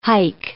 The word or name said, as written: haik